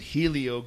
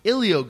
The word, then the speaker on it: Helio: But they pronounce for whatever reason, Helio Gracie, H-E-L-I-O, was pronounced Ilio Gracie. That's how the Portuguese in Brazil use that word, Ilio Gracie.